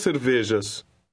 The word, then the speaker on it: cervejas: Portuguese J is pronounced like the middle of the English word measure. Try number 2. – Duas cervejas.